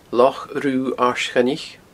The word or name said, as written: Loch Rubha Aird Choinnich